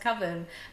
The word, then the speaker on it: Coven: And somebody mentions to him at one point, oh, it's pronounced coven. And he goes, no. Coven, it rhymes with oven.